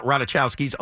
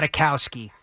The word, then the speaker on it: Ratajkowski: An MSG spokesperson told us on Monday, Page Six, when we reached out to ask about Ratajkowski's alleged recent Ranger ticket request. – Ratajkowski. – What did I say? – You didn't say it right.